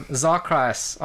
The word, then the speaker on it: Xarcrius: It is X-A-R-C-R-I-U-S. Oh, Z-X. What's the difference? – Z-X-R-Y. – X pronounced like Z.